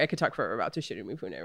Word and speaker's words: Toshiro Mifune: Toshiro Mifune.